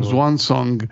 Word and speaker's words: Swansong: Beh, Swan Song.